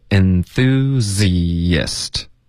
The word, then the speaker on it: enthusiast: 那当一个名词呢，这个字的发音是 enthusiast。 OK，或者当复数的就可以用 enthusiasts， OK，所以复数的就加一个 s 就可以了。它的发音要注意，就是结尾的 z 跟 ist，这是两个音节，中间会添加一个很轻的 y 的音， enthusiast 有一个很轻的 y， theists， theist， OK， enthusiast 四个音节， enthusiast。